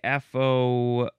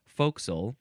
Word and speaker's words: forecastle: which was like FOCSLE. It's pronounced forecastle.